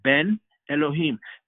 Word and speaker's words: Ben Elohim: The Ben Elohim, that's literally how you say it in Hebrew, Ben Elohim.